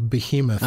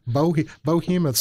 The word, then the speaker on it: behemoth: There's arguments that people will make that it's not, and that it's really moving us closer to the media behemoth. I'm sorry, let me try that again. Media behemoth. There, I said it right that time. Bohemoth sounds like a...